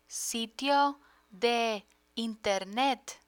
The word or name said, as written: sitio de internet